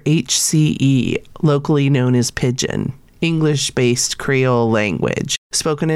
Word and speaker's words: pidgin: I don't know if I'm saying that right, but it's spelled P I D G I N. – I always heard the Hawaiian kids say "pidgin." Okay, good. I'm, I'm at least saying it correctly.